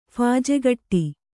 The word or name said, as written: ಫಾಜೆಗಟ್ಟಿ